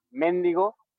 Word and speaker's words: mendigo: O sea, por ejemplo, mendigo, con acento en la primera E, como si fuese esdrújula, que es una palabra que solamente se dice en México, creo.